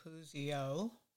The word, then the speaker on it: Puzio: And we also have our YouTube channel, which is under my name, Susan Puzio.